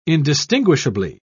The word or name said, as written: indistinguishably